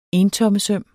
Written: entommes

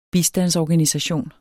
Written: bistandsorganisation

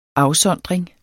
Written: afsondring

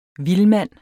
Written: VILDMANDENS

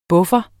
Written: PUFFEREN

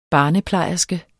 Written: BARNEPLEJERSKES

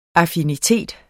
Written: affinitet

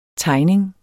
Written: tegning

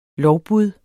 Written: lovbud

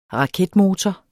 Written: raketmotor

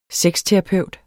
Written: sexterapeut

